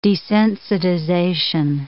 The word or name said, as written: desensitization